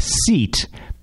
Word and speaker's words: SEAT: And of course, the brand Seat, it looks like seat but it's spelled S E A T, just like seat, but it's pronounced Seat.